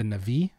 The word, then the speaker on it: Na'vi: You know, it seems like it's being instigated by both humans and the Na'vi.